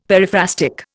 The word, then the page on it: periphrastic: Indian